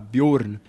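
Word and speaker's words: Bjǫrn: Então, meu nome eu puxei do nórdico antigo, por isso que se escreve com o cedilha, né? O caldata. Então é o arredondado, Bjorn, né? Assim como Mjolnir ou Ragnarok.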